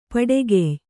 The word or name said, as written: ಪೊಟ್ಟುಗೆಯ್